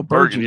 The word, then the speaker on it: Bergen: It sounded like you said Virgin. – No, Bergen. Yeah, Bergen. Yeah, it's in Bergen County. Yeah, I'm bummed. – I pronounce the G as a J.